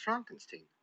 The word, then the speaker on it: Frankenstein: It's pronounced Frankenstein.